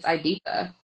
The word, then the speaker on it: Ibiza: That's like saying Ibiza and Ibiza. It's Ibiza. – That is correct.